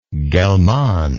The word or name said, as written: gellmann